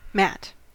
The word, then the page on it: mat: American English